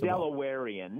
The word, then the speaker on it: Delawarean: Now, are you a lifelong Delawarean or Delawarean? Which one is the correct pronunciation? – Well, they say Delawarean.